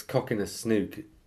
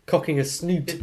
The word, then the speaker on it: cocking a snook: Did I say that right? – Cocking a snook. – Cocking a snoot. – Cocking a snood. That'd be a horrible image, wouldn't it? – No, I think you're right.